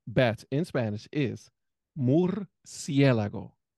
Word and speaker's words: Murciélago: Murciélago. – There you go. And if you want to say it in plural, just add the S at the end. Murciélagos.